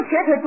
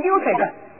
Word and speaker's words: 瘸腿: 什么瘸腿读成溜腿的。哎呀，有一次有个弟兄瘸腿读成溜腿的，读得很得意呢。